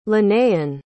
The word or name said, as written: linnaean